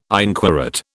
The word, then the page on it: inquorate: British English